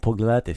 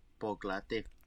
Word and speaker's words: pogledati: Idemo pogledati film? – Idemo pogledati film? – Try it again. Idemo? – Idemo. – Bravo. Pogledati. Let's go with the accent the second syllable again.